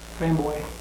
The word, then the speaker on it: La Framboise: Does somebody know how to say that better? La Framboise. – Framboise. – Is that how you say it? Because there are often American ways of pronouncing those fresh framboise. Thank you.